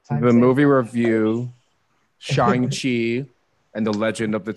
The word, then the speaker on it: Shang-Chi: And I was like, Shang-Chi.